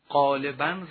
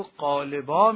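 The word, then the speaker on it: غالباً: اما اگر به یاد گرامی شما مانده باشد، عرض کردیم که کلمات متون رو قدما و بزرگان ما با تنوین تلفظ نمی کردند، ظاهرن رو ظاهرا می خواندند، قالبن رو قالبا می خواندند.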